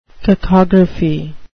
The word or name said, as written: cacography